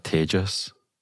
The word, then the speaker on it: Taegis: And how do you spell that, Tages? – I should know this. T-A-E-G-I-S.